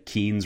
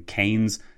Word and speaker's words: Keynes: So I said Keynes rather than Keynes several times.